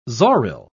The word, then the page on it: zoril: British English